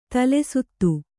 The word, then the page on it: ತಲೆಸುತ್ತು: tale suttu